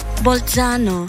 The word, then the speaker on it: Bolzano: Když nevíte, jak se něco vyslovuje, taky to máte tak, že si najdete na YouTube takovýto how to say nebo how to pronounce. Ještě, že to existuje a dá se to propojit i v éteru. – Bolzano. – Ano, tak tam to bude lítat, jo.